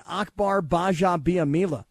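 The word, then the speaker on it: Akbar Gbaja-Biamila: I mean, shout out to my man Akbar Baja Biamila. Love that guy. Anyway, I'm with you, George. – Good job on the pronunciation. Most people don't nail it like you just did.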